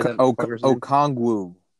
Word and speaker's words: Okongwu: How do you say Okongwu.